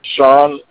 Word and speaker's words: Shan: Sean. – Is it S-H-A-W-N or S-H or S-E-A-N? – It's actually spelled different. It's spelled S-H-A-N. – S-H-A-N. – But it's pronounced Sean.